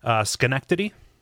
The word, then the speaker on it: Schenectady: Schenectady. – Schenectady? – Yeah. – Okay. Because I thought it wasn't that, because that's the city. – Yes. No, it's Schenectady versus Synecdoche.